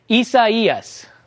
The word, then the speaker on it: Isaias: This is how you say it. Isaias.